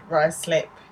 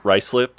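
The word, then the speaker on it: Ruislip: Ryslip, yeah. – Ryslip, okay. Well, why didn't you just spell it like it should be pronounced then?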